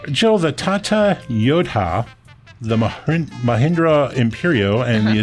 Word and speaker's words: Yodha: The Tata Yodha. – Spell that. – Y-O-D-H-A.